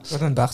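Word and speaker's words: Laudenbach: Lodenbach. Je ne sais pas s'il se prononce bien. – Lodenbach, c'est ça.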